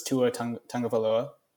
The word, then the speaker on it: Tua Tagovailoa: Most important of those draft picks was Tua Tagovailoa from the University of Alabama.